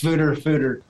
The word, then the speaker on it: foeder: But so anyway, that's why I use, pronounce foeder, use the Dutch pronunciation.